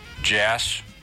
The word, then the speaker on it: Jhass: My last name is Jass. – How do you spell that? – J-H-A-S-S, Jass.